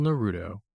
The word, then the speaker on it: Naruto: We are finally delving into a little Naruto and building Uchiha Sasuke.